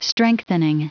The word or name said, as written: strengthening